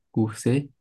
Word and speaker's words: courses: Vous faites des courses? – Course. – Courses? – 但哈，Jean 这个 S 是不发音的。我们刚刚不是说它不能算是字尾吗？这个 S 在字尾的话呢，它就是一个语法上的 s，它不是一个发音上的 s 啊，所以它不能算是字尾啊。